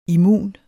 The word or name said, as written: immun